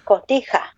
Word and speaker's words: Cotija: Cotija. You gotta pronounce so. Co. – Co. – T. – T. – Ha. – Ha. – Yeah. Cotija.